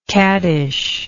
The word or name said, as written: caddishness